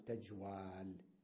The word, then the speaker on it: تجوال: قال استثنوا هذه الكلمة من القاعدة وقالوا تبقى الواو واوا ولا تقلب ألفا، تمام؟ فقالوا تجوال.